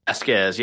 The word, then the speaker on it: Vazquez: Am I pronouncing that right? – Vazquez, yes.